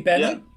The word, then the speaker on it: Benin: Is that, am I pronouncing it correctly, Benin? – Yeah, Benin Republic.